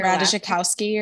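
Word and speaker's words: Ratajkowski: I just finished reading My Body by Emily, I don't know how to say her Radishikowski or something. – Yes, that's how you say it, yes.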